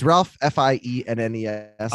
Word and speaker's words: Fiennes: Okay, it's Ralph Fiennes. – Oh, Ralph Fiennes. Fiennes.